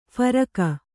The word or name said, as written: ಫರಕ